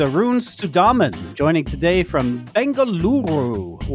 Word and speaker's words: Bengaluru: Pleasure to be here, and very well done on pronouncing Bengaluru. I didn't roll the R.